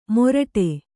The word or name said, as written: ಮೊರಟೆ